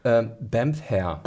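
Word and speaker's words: Damphair: Dampf Herr. So, also Dampf wie Klamm und Herr wie Haar.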